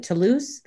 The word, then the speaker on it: Toulouse: and what I had learned about is it Toulouse? That's how you mm-hmm. pronounce. – Yeah.